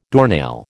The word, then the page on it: doornail: American English